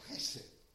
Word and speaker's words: chesed: Sometimes, spelt with a C, the pronunciation is a bit between k and h. It's h, chesed.